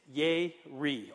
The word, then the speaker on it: yare: The word in Hebrew for the word fear is yeri. Okay, yeri. We would spell it like Y-A-R-E.